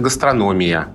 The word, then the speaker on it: гастрономия: А про гастрономию словари говорят, что это гастрономия. И только с таким ударением и что Динер тоже пишет, гастрономия — это единственный вариант.